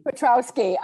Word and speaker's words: Piotrowski: Piotrowski.